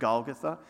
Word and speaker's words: Golgotha: some of you have heard the word Golgotha, or however you pronounce it, right? I, I pronounce it Golgotha.